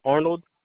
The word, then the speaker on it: Arnold: Arnold. – Can you spell that for me? – A-R-N-O-L-D. – You said A-R-N-O-O-D? – O-L-D.